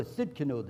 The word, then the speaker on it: Tsidkenu: Number four, Jehovah Tsidkenu, the T is silent, T-S-I-D-K-E-N-U, the Lord is my righteousness.